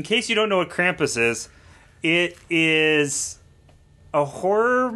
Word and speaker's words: Krampus: I think that's how you pronounce it. – Krampus, yeah.